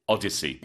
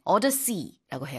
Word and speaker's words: Odyssey: 놀란 노란 감독을 얘기하고 있는 거고 그다음에 이제 오디세이라고 우리는 하는데 영어로는 Odyssey. – 네, 오더시라고 해요.